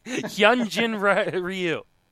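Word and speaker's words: Hyun-jin Ryu: I'm interested in you trying to pronounce that name again. – Hyunjin Ryu. There you go.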